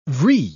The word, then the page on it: vrille: British English